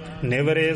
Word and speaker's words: निवरे: पहला पाठ निवारे ते दूसरा निवरे है। दोवां पाठां नूं जिवें ज्ञानी जी ने विशेष ध्यान दे के उच्चारण कीता है, काम क्रोध लोभ मोह निवारे निवरे सगल बैराई, दोवां नूं ध्यान दे नाल उच्चारण करना जी।